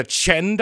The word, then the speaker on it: Qing: It's the Chen dynasty, but it's with a Q. – Wait, it's what is it? – It's like the Qin dynasty, but it's spelled Q-I-N-G.